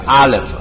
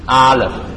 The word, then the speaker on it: Aleph: 咁教大家一個希伯來文字，中間個 N 字呢就唔係 N 字嘅，係希伯來文字嘅，咁讀法叫 Aleph，一齊讀一次吧。 Aleph，有啲讀音係 A-L-E-P-H，見到一齊讀吧。